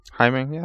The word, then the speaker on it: Jaime: Jaime, that's pretty close. Jaime.